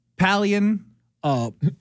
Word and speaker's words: paleontology: Paleontology.